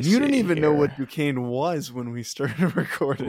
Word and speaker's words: Duquesne: Duquesne is pronounced Duquesne, not Duquesne.